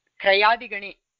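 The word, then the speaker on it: क्र्यादि: अयं धातुः क्रयादिगणे, क्रयादिगणे अस्ति। अत्रापि पश्यन्तु उच्चारणं कथं भवेत्, ककारः यकारः रेफः एकत्र दृश्यते, क्रियादि इति वक्तव्यम्।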